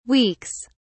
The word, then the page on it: weeks: British English